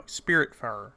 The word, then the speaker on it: Spiritfarer: and Spitfarer. I believe I'm saying that. Or, no, Spiritfarer.